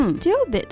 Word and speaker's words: stupid: 那要注意啊，这里的字母 T 跟在字母的 S 后面会有一个不送气的啊这么一个发音，我们读成类似于的的这个发音哈。 – 嗯 Stupid 就是一个很基本的用来形容人很笨呀、很傻的这么一个单词。